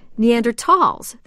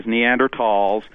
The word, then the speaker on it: Neanderthal: Originally, it was the word is the German word for valley, and it was spelled T-H-A-L, mm-hmm. but it was always silent. So when the German spelling changed in 1901, the word for valley was T-A-L, dropping the H. But either way, whether the H is there or without it, it is always pronounced Neanderthal.